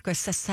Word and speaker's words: Scorsese: Scorsese. – What? Am I saying it wrong? – No, you're saying it right.